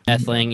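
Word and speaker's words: Ætheling: No, I mean, yeah, that's pretty it's right, because Alfred dies, Edward is trying to take the throne as the Etheling, right? That's how you say it. Etheling, yeah.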